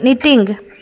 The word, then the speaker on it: knitting: ನಿಟ್ಟಿಂಗ್ KNITTING ನಿಟ್ಟಿಂಗ್.